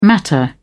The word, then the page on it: matter: British English